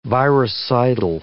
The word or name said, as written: virucidal